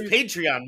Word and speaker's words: Patreon: You got to stop calling it Patreon. – I don't know what to call it. I never. Tell me it's Patreon, motherfucker.